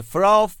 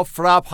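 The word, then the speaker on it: FRAPH: فراف با اسپل F R A PH شبیه گراف نوشته میشه فقط اولش یک F بذارید توی بعضی زبون ها فرپ هم تلفظ میشه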